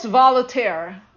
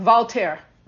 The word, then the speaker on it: Voltaire: He quotes Voltaire. I'm saying that wrong. Voltaire. Voltaire, who once said, whatever his personal view on religion is, he wanted his attorney, tailor, and servants to believe in God.